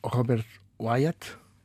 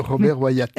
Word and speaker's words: Robert Wyatt: Hum, uma coisa assim leve e, e, e, agradável que poderia ser o Robert Wyatt. É assim que se pronuncia. – Sim, sem ser em francês, Robert, uh, Wyatt, é Robert Wyatt.